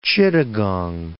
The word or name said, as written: Chittagong